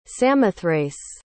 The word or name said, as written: samothrace